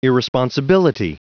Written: irresponsibility

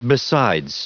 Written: besides

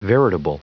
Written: veritable

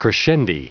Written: crescendi

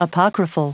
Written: apocryphal

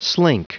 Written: slink

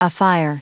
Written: afire

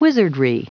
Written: wizardry

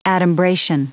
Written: adumbration

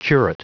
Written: curate

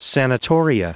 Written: sanatoria

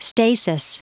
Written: stasis

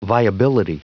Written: viability

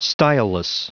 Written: styleless

